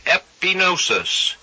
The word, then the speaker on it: epignosis: The word knowledge here, we find out, is the word, if we go to the Greek to get it, it is a Greek word that is pronounced epignosis. It is E-P-I-G-N-O-S-I-S, and it means a recognition or acknowledgement, not a personal experience knowledge.